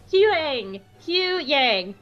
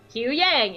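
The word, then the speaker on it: Huyang: I know his name! It's Hu Yang! Hu Yang, it's two syllables.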